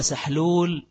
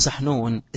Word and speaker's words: سحنون: يبقى كنت بقرأ مرة بعض إخواننا أو حضرت درس، فبعض إخواننا كان بيقول يعني وقال سحلول من الملائكة، من الملكي سحلول، ماشي اسمه سحنون، اسمه سحنون، فقال سحلول ماشي سحلول ده من الملكية، يبقى الاسم نفسه مش عارف ينطق.